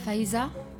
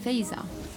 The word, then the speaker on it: Faiza: In France we say Faiza, but the Arabic pronunciation is Faiza.